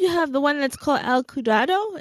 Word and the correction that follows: Ciudad: if I'm saying that correctly. Oh, see, my Spanish is horrible. – Ciudad, City of Drama, Ciudad del Drama. – Oh, see, you said it so nicely.